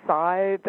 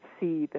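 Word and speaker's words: seiðr: Sometimes you'll hear it pronounced scythe. Of course, nobody really knows how Old Norse was pronounced. But I think the now linguists kind of have veered toward the saith pronunciation. Sometimes people pronounce it seeth, and that is not.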